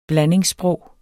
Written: BLANDINGSSPROG

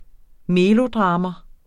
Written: melodrama

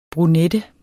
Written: brunette

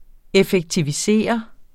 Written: EFFEKTIVISERE

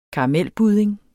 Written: karamelbudding

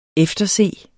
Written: efterse

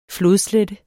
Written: flodslette

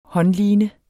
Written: håndline

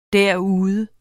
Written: derude

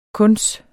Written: kuns

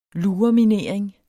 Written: lureminering

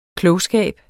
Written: klogskab

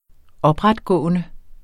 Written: opretgående

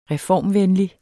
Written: reformvenlig

